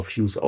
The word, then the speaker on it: FYUS: Und ja, Fuse aus Aachen, besagte Band, oder Fuse, Entschuldigung, FYUS geschrieben, aber Fuse ausgesprochen.